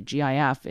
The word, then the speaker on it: GIF: GIF is like gift, right? That a gift, a present that you would give someone. Like, it's a hard G, and people are like, what about giraffe? And I'm like, giraffe starts with G-I-R.